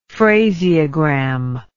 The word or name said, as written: phraseogram